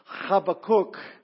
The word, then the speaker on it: Habakkuk: Some pronounce it Habakkuk. I'm told from our in-house linguistics expert, Pastor Bruce, that the proper pronunciation is Habakkuk.